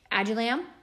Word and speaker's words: Adullam: Adullam. A-D-U-L-L-A-M.